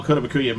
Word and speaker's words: Kotobukiya: You have behind me the Kaitabashi statue of the first set. – Kotobukiya. – Yeah, whatever. Of the first set of armor. Can you give me that? I don't know how to pronounce the name. – Kotobukiya. – Kotobukiya. Okay.